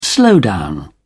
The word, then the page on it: slowdown: British English